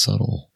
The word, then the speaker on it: subtle: They were a little more subtle, I think. By the way, that B is silent. It's pronounced subtle.